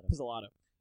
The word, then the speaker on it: Pizzolatto: Pizzolatto.